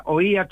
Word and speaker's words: oía: Damos por válida Oía, teniendo en cuenta que lleva tilde, que lleva tilde, porque el acento es solamente el golpe de voz. Claro. Y Oía... lleva tilde porque es acento de voz más signo gráfico.